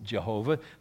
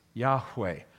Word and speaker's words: Yahweh: The Hebrews could not pronounce it Jehovah. They don't have a J or a V in their alphabet. His name is Yahweh.